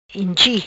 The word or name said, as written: Inchi